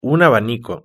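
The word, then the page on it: un abanico: Spanish (Spain)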